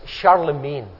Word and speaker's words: Charlemagne: You pronounce this Charlemagne.